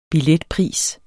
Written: billetpris

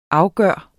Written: afgjort

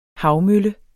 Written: havmølle

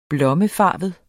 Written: blommefarvet

